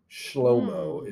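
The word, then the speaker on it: Shlomo (שלמה): Shlomo is really how you pronounce Solomon in Hebrew.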